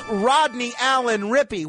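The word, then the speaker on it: Rodney Allen Rippy: I think it's Rodney Allen Rippey, the child star. – Well, I am sorry, Lieutenant G, but that is absolutely right. It is Rodney Allen Rippey.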